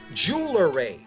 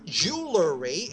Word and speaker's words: jewelry: I cringe when I hear radio or TV ads with the announcer telling me where to get a great deal on jewelry. It's not jewelry, it's jewelry. It comes from the word jewel, not jewla.